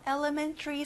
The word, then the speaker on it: elementary: 자, man의 강세를 넣어보세요. Elementary.